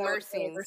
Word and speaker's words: myrcene: And, you know, it's high in, is it myrcene, micrine? I've had so many different pronunciations of right. – that. – I've been saying myrcene. – So. Sounds right. But, yeah.